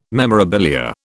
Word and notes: memorabilia: British English

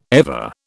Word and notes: ever: British English

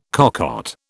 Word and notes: cocotte: British English

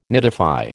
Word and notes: nidify: American English

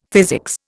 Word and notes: physics: Indian

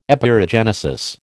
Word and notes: Epeirogenesis: American English

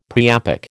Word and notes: priapic: American English

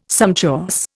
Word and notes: sumptuous: Indian

